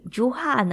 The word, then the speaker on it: Jura: Huse Maton，因为他们的名字呢，真的有一点难念了，因为 R 不念 R，有点发音，发音有点像 H 的发音，所以呃，朱哈呢，其实它是 J U R A，但是在那里呢，他们都是念朱哈，所以我们也跟着念朱哈啊哈，尊重他们原有的那个发音。